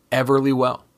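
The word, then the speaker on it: Everlywell: Everly Well. I actually pronounced that one right.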